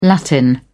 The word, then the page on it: latin: British English